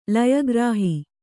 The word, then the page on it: ಲಯಗ್ರಾಹಿ: laya grāhi